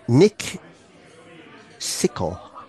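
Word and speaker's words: Nikšićko: But the name of the beer was called, I'll spell it for you, Nikisicko. Nick Sicko.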